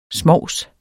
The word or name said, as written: smovs